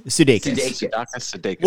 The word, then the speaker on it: Sudeikis: How does he say his name? – Sudeikis.